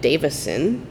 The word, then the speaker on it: Davison: So June 4th, 1913, Emily Wilding Davison, not David. – Davidson, yeah. Which has been like, I keep saying it wrong.